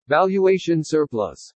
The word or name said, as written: valuation surplus